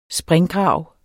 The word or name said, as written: springgrav